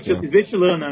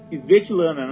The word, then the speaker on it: Svetlana: Stevlana Kodokcheva, alguma coisa assim. – Nossa, é Svetlana, né? – Como é que é? – Svetlana, né? – Svetlana Kodokcheva.